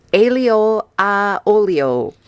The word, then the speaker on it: aglio olio: Now, I'll probably butcher this name, but it's spaghetti aglio olio.